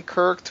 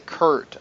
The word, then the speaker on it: Kirk: Our goal was to sign Kirk to a long-term contract. He does say Kurt about 20 times, though, so he says his name wrong.